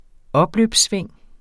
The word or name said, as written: opløbssving